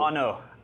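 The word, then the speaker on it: An Oa: And now Mick is going to come around with a little taster of the Anoa. – Yeah. – Is that how I pronounce it? – Terrible. Anno.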